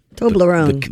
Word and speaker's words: Toblerone: And do you pronounce it Toblerone? – Toblerone. – The candy? – I think everybody in the world says Toblerone.